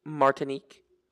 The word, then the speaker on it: Martinique: Just want to point out, correction, yeah. It's Martinique. – Martinique, sorry. Thank you for that.